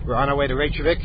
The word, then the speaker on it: Reykjavik: Yes, and I can say Reykjavik because I pronounce the J. And if we have gotten some cards and postcard, even a floral bouquet of somebody, thank you, by the way, asking me to stop pronouncing the J. But I'd like, the J is there. What's it there for if you can't pronounce it? And I think that might be a valid way to pronounce Reykjavik.